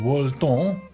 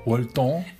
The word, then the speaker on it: Wolton: Er is ehm, nog niet zo lang geleden een interviewboek verschenen in Parijs van een Franse intellectueel, Dominique Walton. Wolton.